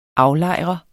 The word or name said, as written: AFLEJRET